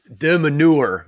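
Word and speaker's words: de Minaur: He's going to look, he's got a much better serve than Dimenauer, I've been told. That's how you pronounce his name. Someone on Twitter was all over me because I've been mispronouncing it.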